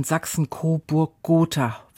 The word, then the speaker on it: Sachsen-Coburg-Gotha: Also ganz besonders mit der von Herzog Karl Eduard von Sachsen-Coburg-Gotha. Wenn ich das jetzt richtig gesagt habe. – Ja, das hast du richtig gesagt.